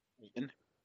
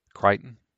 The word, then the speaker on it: Crichton: Is that how you pronounce it? How you, how you pronounce that? Say it again. – Crichton. – Crichton. Yeah. Okay.